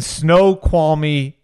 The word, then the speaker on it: Snoqualmie: He's coming to us from his Mercedes van, like a Sprinter van, in Snoqualmie, Washington, right? – That's very good.